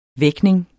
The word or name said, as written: vækning